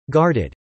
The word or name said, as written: guarded